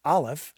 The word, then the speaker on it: Aleph: And the first symbol that they recognized was the symbol for the Hebrew letter A, which is pronounced Aleph, and it was the symbol of the void, that which comes into being from that which is without any being at all.